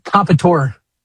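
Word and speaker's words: Comperatore: Corey Compitore.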